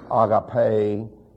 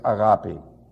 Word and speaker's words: agape: Now, you'll hear pronounced agape and all that nonsense. Take it from me, it's agape. It's not a guppy either.